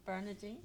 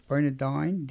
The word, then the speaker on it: Bernardine: Okay, so let's talk about Saint Bernardine. Oh, am I saying that correctly? – Bernardine. – Bernardine, D-I-N-E.